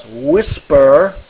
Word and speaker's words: WSPR: WSPR, or as it is pronounced, Whisper, is a software set that is used for weak signal communication, just like Fred said, between amateur radio stations.